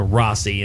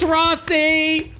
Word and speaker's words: Rhossi: Well, it's spelled R-H-O-S-S-I, so I could have, you know, the vowel and two consonants in between another vowel. I should have probably shortened that out to Rossi and not made Rossi. A long O, yeah.